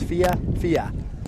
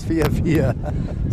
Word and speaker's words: FIFA: It is pronounced FIFA. Two syllables, FIFA.